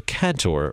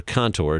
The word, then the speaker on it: cantor: First of all, I'd like to start with cantor or contour, depending on what part of the country you're in or what part of the world you're in and how you pronounce it.